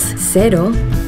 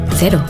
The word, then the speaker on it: Cero: In Yucatan, the local Mexican surfers pronounce cero, cero. During the warm summer evenings in bars around the coast of Nayarit, you'll hear them say cero. In New Zealand, zero alcohol Corona cero is pronounced cero.